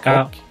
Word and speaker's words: Koch: Er det riktig uttalt det? Må jo være Koch. – Ja, det er det.